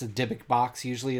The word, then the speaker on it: Dybbuk: Dybbuk.